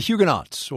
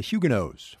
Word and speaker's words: Huguenots: you find the Huguenots, or as the Brits say, the Huguenots, French Protestants who, if history is a guide, have been some of England's most successful arrivals.